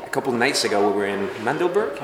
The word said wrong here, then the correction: Magdeburg: Is that how you say it? Uh, Magdeburg. – Magdeburg.